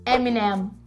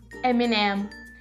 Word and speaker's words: Eminem: Olha bem, presta atenção na minha boca. Eminem. Eminem. É muito parecido com M&M.